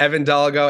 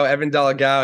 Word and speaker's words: Evan Dolgow: I'm the only one in the world, so I accept all versions of it. But Evan Dolgow, Evan Dolgow, it all works with me.